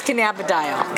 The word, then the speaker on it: cannabidiol: You know, it's a very long, very, very long medical name, but it's cannabidiol.